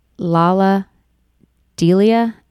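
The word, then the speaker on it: Lalah Delia: It's by, I'm going to say her name wrong, Lala Delia, LALAHDELIA.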